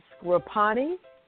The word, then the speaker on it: Sgroppino: The Scroppini. It's spelled Sgroppino.